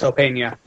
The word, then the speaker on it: Opeña: Weaver and Opeña, if I'm pronouncing that correctly. Is it Opeña? – Yeah, it's Opeña.